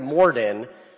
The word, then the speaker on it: Morden: Is that how we pronounce it, Morden? – Yeah.